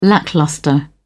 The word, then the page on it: lacklustre: British English